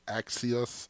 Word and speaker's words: Axios: I believe you pronounce it Axios. Please forgive me for the mispronunciation of this particular show. It's A-X-I-O-X-S. Excuse me, A-X-I-O-S.